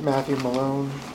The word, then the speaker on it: Matthew Malone: Lieutenant Matthew Malone, M-A-T-T-H-E-W-M-A-L-O-N-E.